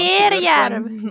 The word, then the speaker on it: Terjärv: Jag vet inte, säger man det så? Terjärv? Nu måste man ju säga det så. Jag är alltid livrädd. – Jag är inte Österbotten. – Jag är livrädd över att uttala österbottniska ordsamt, för man gör det alltid fel och sen är alltid någon Terjärv. – No, exakt.